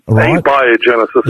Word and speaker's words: abiogenesis: Evolutionists, there's a science called abiogenesis. I hope I said that rightly. – Abiogenesis.